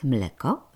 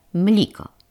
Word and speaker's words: mleko: A mianowicie zamiast mleko wymawia mliko – Takiej burzy dawno nie było w naszym pokoju.